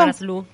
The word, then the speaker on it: Manaslu: Manaslu la octava montaña más alta del mundo y también eh, ubicada en el Himalaya.